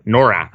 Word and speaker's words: Norath: I want to say Norax. That's not right. Norath. I was close. I was like one syllable off.